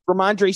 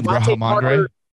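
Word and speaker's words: Rhamondre: Ramondre Stevenson ended up leaving with an ankle injury. – You mean Ramondre? – Ramondre, yeah. – Ramondre. – Sure, Ramondre. – There it is. Say it right, dude.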